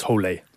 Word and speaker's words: Hole: In the movie, it's just Hole, but I think the correct pronunciation in Norwegian is Hole.